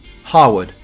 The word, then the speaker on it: Harewood: The eight fellows accept that the drivers do not know what you're talking about when you say Harwood. Lord Harewood said. His agreement to start pronouncing the first syllable as here,